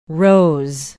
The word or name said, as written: Rose